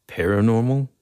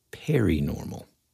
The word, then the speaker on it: paranormal: Dude, I don't know if you do this every time, but you just said paranormal. You pronounced it perinormal.